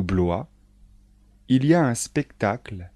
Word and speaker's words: Blois: Les plus célèbres sont les châteaux de Chambord, de Blois, de Chenonceau et le château d'Amboise. Attention de bien prononcer les noms de châteaux. Répétez après moi.